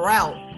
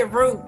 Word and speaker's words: route: Route or route? – You talking about the road? – Yeah. Well, how you say it? – Route. – Route? Okay. Not route, but route. I hate when people say root.